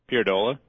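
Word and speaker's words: Pierdolla: Pierdola.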